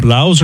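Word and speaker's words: Blough: Blau's are going to Blau. – The O-U-G-H is pronounced.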